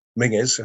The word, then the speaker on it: Menzies: Mingus, a pronunciation of the Scottish name Menzies, rather than C.